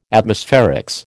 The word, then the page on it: Atmospherics: American English